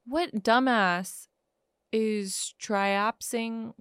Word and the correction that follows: traipsing: How do you say traipsing? – That word?